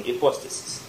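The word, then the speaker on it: Ипостасис (ὑπόστασις): Эльгазоменос ипостасис. – Осуществление ожидаемого. – Осуществление ожидаемого, да. И по-советски извещение уповаемого. – А читается ипостасис? – Ипостасис. Ипостасис это в университете.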